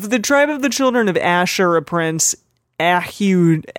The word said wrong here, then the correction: Ahihud: How would you say this? Ahihud. – Ahihud. – Ahihud. Okay.